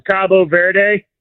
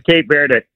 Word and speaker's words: Cabo Verde: Either way, you said it right. That's the it's kind of like Turkey, Turkey. – Yeah. – They in Spanish, it's Cabo Verde. – Cabo Verde. – Cabo San Lucas or whatever in Mexico. Cabo Verde. But okay. – The English pronunciation is Cape Verde.